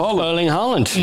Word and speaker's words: Erling Haaland: Erling Haaland.